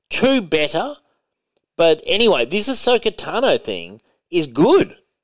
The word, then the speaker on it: Ahsoka Tano: Ahsoka Katano. Is that how you say it? – Ahsoka Tano.